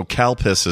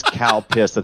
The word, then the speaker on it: Calpis: So Cal Piss is... What is Cal Piss? – It's a milk-based beverage. – I think saying it incorrectly. I don't think it's Cal Piss.